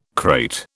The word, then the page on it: krait: British English